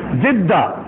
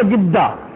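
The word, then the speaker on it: জিদ্দা (جدة): তো এরকম ভাবে জিদ্দা মিশরীদেরকে জিজ্ঞেস করবেন তারা বলে গিদ্দা গিদ্দা, জিদ্দা বলতে পারে না।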